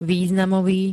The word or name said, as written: významový